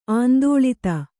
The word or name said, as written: ಆಂದೋಳಿತ